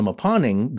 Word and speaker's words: Mponeng: The deepest mine in the world is the Mponeng gold mine in South Africa, and that is spelled M P O N E N G.